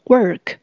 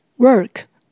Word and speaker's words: work: work 工作，请留意它的发音，这边的 or 是发 er 的卷舌音。 work work，我们用现在进行式来表达现在当下的动作。